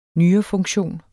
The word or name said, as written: nyrefunktion